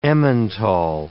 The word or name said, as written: Emmental